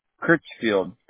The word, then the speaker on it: Crihfield: Kyle, your last name is that C-R-I-H-F-I-E-L-D? – Yeah, C-R-I-H-F-I-E-L-D, yes. – How do you say that? – Kirchfield.